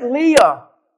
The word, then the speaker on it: Leah: You see, when I look at Leah, the word Leah may sound beautiful in the Hebrew. It's pronounced Le'ah.